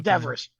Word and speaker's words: Devers: Devers, that's a Devers.